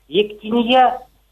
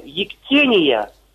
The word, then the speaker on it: ектения: Почему сейчас даже клирики, вплоть до архиереев, говорят «ектинья», «литья», ударение ставят на букву «я», через мягкий знак говорят. – А как с вашей точки зрения правильно? – Ну, правильно, как я знаю, «ектения», ударение на второй слог.